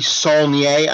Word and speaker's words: Saulnier: The name is Jeremy Saulnier. I don't know if that's how it's pronounced.